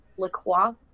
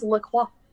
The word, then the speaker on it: LaCroix: It's actually pronounced LaCroix, I guess. – Are you serious? – It's pronounced LaCroix. – LaCroix? – It's French LaCroix.